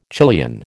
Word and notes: Chilean: American English

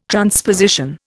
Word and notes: transposition: Indian

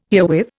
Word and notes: herewith: Indian

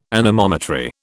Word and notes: anemometry: British English

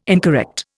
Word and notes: incorrect: Indian

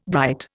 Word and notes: bright: Indian

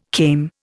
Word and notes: came: Indian

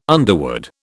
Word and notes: underwood: British English

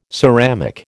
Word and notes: ceramic: American English

